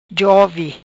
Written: Jovi

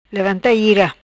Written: Levanta Ira

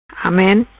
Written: Amen

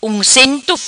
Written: Uncento

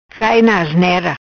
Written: Cai Na Asnera

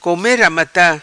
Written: come ramata

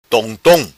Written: Tom Tom

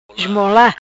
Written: Esmola

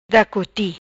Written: Da coti

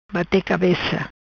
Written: Bate cabeca

